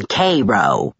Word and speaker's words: Cairo: "That's how they pronounce the one in Egypt. The one in Little Egypt. They call that one Cairo.